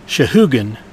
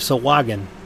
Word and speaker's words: Sahuagin: Shahugan instead of Sahuagin.